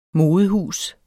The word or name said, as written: modehus